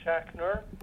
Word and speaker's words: Schachner: Jeffrey Schachner, S as in Sam, C-H-A-C-H, N as in Nancy, E-R.